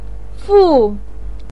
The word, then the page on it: fu4: pinyin